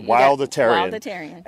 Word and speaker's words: Wildatarian: But so I got this book called The Wildatarian Diet, and am I pronouncing that right? – That's right. – Wildatarian.